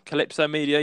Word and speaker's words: Calypso Media: So the news is about Calypso Media. – Calypso Media. Yep, he's done it right. He managed to pronounce it.